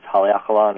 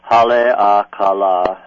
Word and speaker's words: Haleakala: Yes, it's Haleakala National Park. – Would you say that again? – Haleakala, House of the Sun, Ka of the La Sun.